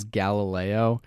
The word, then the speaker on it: Galileo: And there was a time where, like, the answer to a test question was Galileo. And to try to help us out with it, he said it rhymes with red and yellow.